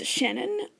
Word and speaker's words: Shannon: So, if you choose to use it, my email address is Shannon, S-H-A-N-N-O-N, @Sympatico.ca. And Sympatico is spelled S-Y-M-P-A-T-I-C-O.